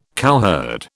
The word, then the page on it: cowherd: British English